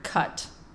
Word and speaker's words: Cut: When I tell people my last name, I say, spell the word cut. – C-U-N-T. – No, not cunt. Cut. – C-U-T. – That's how you pronounce it. – What? – You see? What? – C-U-T. – C-U-T.